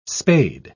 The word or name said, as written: spade